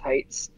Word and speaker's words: HYYTS: HYYTS is the very pretentious spelling of our band's name. It's pronounced Heights.